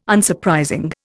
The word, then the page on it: Unsurprising: Indian